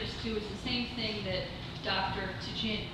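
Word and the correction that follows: Tjaden: Jaden. There's the T is silent.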